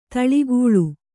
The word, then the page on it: ಧಾಳಿಗೊಳ್: Kannada